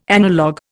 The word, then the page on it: analog: Indian